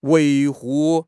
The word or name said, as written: 维护